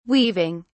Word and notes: weaving: British English

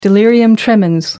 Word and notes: delirium tremens: American English

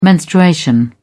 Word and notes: menstruation: British English

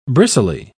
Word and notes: bristly: British English

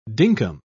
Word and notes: dinkum: British English

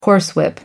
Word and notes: horsewhip: American English

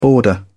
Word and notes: border: British English

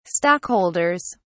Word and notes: stockholders: British English

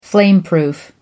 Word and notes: flameproof: American English

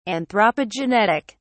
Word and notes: anthropogenetic: British English

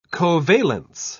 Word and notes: covalence: British English

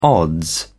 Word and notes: odds: British English

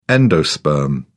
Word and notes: endosperm: British English

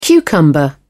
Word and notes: cucumber: British English

